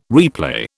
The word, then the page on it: replay: British English